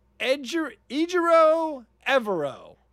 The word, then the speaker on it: Ejiro Evero: Ejiro Evero.